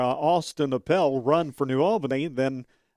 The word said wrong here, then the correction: Opel: Austin Opel. I want to get his name right. – What did I say this time? Appel again? – Yes.